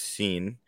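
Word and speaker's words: Cine: And Lewis Seen, it looks like it's signed, but it's pronounced Seen.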